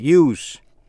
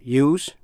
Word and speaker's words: use: Vậy chữ S trong danh từ use hay trong tính từ useful hay là useless? Chữ S đọc với âm S. Khi là động từ use thì chữ S phát âm là Z.